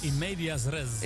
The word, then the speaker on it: in medias res: En hoe spreek je het eigenlijk uit? In Matthias Rest? – In Medias Res.